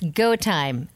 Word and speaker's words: GoTyme: It's GoTime. It's spelled GoTyme.